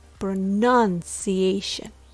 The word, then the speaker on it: pronunciation: The word pronunciation has the vowel uh in the second syllable. Uh, pronoun, pronunciation. The word pronounce has the vowel ow in the second syllable. Ow pronoun, pronounce, or pronouncing.